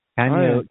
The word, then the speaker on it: Kanye: Kane West. He's a rapper. – Kanye. – See how out of you got to get with it, Joe. – See how out of touch I am. With Kane. It's spelled Kane. Yeah. Oh, no, Kanye. Okay. – Kanye. – I got the N and the Y backwards.